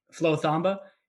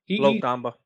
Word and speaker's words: Flo Thamba: Flo Thamba. – Flo Thamba.